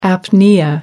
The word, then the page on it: apnoea: American English